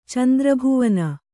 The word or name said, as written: ಚಂದ್ರಭುವನ